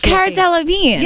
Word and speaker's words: Cara Delevingne: Somebody tweeted us and tell us how to pronounce her last name. It's like Delvigny or Delvigny. – Honey, whatever she's doing, she's delicious. – Cara Delevingne.